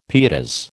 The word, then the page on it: pietas: American English